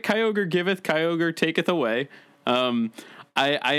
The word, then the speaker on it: Kyogre: So for is it Kyogre? Is that how you pronounce it? – Yeah. – I only know Kyogre from being a Smash Brothers Pokeball that just fucks up everything.